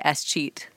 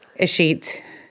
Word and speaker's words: escheat: First thing I needed to ask you is, how do you say escheat? – Escheat.